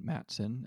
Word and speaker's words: Mattson: So you spell it like the name Matt, M-A-T-T S-O-N, Matson, and then the number 2.